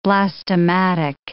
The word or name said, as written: blastematic